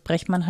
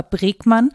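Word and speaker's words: Bregman: Also ich glaube, dass es Brechmann heißt, weil er Niederländer ist. Für kartoffelig ausgesprochen wäre es Rutger Bregmann, aber ich glaube, es ist Brechmann.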